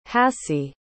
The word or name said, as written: hassie